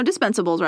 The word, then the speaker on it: dispensable: Dispendable? Did I say that wrong? – No, dispensable's right. – Okay. – Because you can dispense of him. Yeah. Dispendable, I think, is wrong.